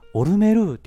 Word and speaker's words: オルメル: で、ショーを見た時に、オルメルって言うんですよ。えーとか思って。マジでえ、それ、正式の発音なんえ、オルメルっていうのみたいな感じでめっちゃこう、なんか、そわそわしてたんですよ。